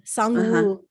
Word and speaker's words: Sang-woo: Sang, like it's a soft S. And then a soft G, like sang, but like sang. – Sang-woo.